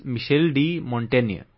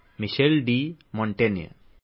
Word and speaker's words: Michel de Montaigne: The pronunciation is Michel de Montaigne, but the spelling is M-O-N-T-A-I-G-N-E, Michel de Montaigne.